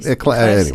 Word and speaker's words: Ecclesiastes: And the lyrics come directly from the book of Ecclesiastes. How do you say that? – Ecclesiastes.